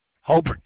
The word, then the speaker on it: Hobert: And you said your first name is spelled H-O-L-B-E-R-T? – No, H-O-B-E-R-T. Just like Robert, he only had an H instead of an R.